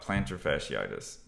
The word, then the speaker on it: plantar fasciitis: Well, we're here today to talk about plantar fasciitis.